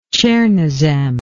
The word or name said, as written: tschernosem